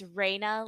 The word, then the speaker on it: Rayan: I always say it's Raina, like ray of sunshine, not rain on your parade.